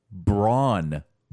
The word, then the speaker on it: Bron: and they brought him out as Bron Breakker, Bron Breakker, Bron Breaker. Two Ks. Bron, B R O N, like yes, Tron.